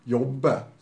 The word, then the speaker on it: Jobe: Och jag är osäker på uttalet på efternamnet där. Stavas Jobe. Job. Men Sheriff. – Jobbe, står alltså på efternamnet.